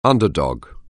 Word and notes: underdog: anglais

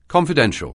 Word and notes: confidential: anglais

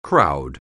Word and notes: crowd: anglais

